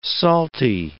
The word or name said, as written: salty